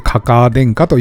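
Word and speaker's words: 嬶天下: あれも女編に鼻で天下と書いてかカ殿下と読むそうですよ。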